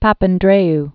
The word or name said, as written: Papandreou